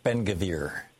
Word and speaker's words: Ben-Gvir: And they brought up that political leader, the security minister, Itamar, hope I'm pronouncing this right, Ben-Gvir. – Yeah, Ben-Gvir.